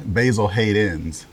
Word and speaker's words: Basil Hayden's: I said it wrong, but say it again. – Basil Hayden's, plural. – Exactly. – A lot of people just call it Basil Hayden.